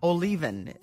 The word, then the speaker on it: Olivan: We're chatting now with Laura Olivan. Did I say your name correctly, Laura? – Olivan. – Okay. Just the way it's written.